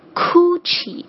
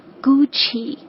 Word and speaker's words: Gucci: OK，第二个也是进入中国这个年头比较多的一个牌子了， Gucci，我听到蛮多中国人会喜欢把第一个音发成 Gucci，不知道这个 K 的音是哪儿来的，正确的发音应该是 Gucci。